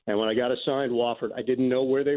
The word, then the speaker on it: Wofford: So when I got them this year, the first thing that I remembered was that I pounded it into my head, the pronunciation. W-A-H-F-E-R-D. Wofford. Wofford.